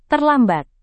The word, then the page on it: terlambat: indonesian